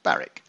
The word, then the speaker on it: Barwick: This is Barrett Green, spelled Barwick, pronounced Barrick.